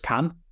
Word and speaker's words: Cannes: Because fairly recently I saw Anatomy of a Fall, which, if you're not aware of it, it won the Palme d'Or at Cannes. Or just Cannes. Let's pronounce it properly.